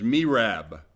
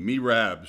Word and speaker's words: Merab: And in the Cambridge, it has Merab, long E, Merab, Saul's daughter.